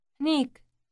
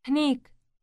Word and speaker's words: هنيك: هنيك. هنيك. – This word starts with an H sound followed immediately by an N sound. Listen again and repeat. – هنيك.